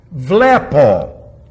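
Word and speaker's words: blepo: Vlepo. Accent over the epsilon. It's not, if it was at the omicron, then it, or rather the omega, it would be vlepo.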